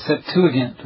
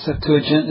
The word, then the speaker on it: Septuagint: The second one is a Greek version known as the Septuagint. Or Septuagint, it's pronounced several different ways,